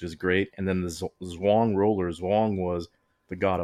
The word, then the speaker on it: Zhurong: But uh, its rover is the Zhurong rover. I'm sure there's not two syllables in that. Z H U R O N G, as Sean Esquire pointed out.